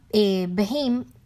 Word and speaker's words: Bheem: Eh, hay uno que se llama Behim, que se escribe B-H-E-E-M.